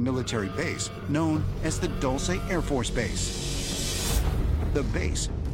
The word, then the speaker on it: Dulce: We still can't figure out how to pronounce it in New Mexico, but it's an Air Force Dulce. – Base. Dulce. This sweet base.